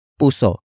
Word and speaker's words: puso: Iyong is spelled as I-Y-O-N-G. It means your, Y-O-U-R, in locative possessive form. After that, we have puso. Puso. Puso. Puso is spelled as P-U-S. Oh, note the glottal stop at the end.